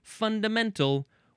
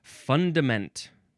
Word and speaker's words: fundament: The third form of fund is an abbreviation for the word fundamental, which is coming up. Fundament. Now, the emphasis is on the first syllable.